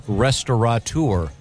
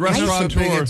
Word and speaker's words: restaurateur: I pronounce it restaurant in my head, and it's that gives you the T-A-U there before you go. – Oh, okay. – I know that the most mispronounced is restaurateur. – Mm-hmm. – There's no N. It's not restaurateur.